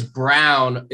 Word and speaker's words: Braun: It's Brown. It's pronounced Brown. It looks and spells like Braun.